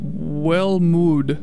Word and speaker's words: Welmoed: Before that, you heard the band, I believe it's pronounced Wellmood, Wellmood, W-E-L-M-O-E-D.